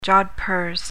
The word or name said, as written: jodhpurs